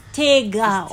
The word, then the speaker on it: Tega: My name is Tega. Tega. – Like, take Tiger. – This. Take.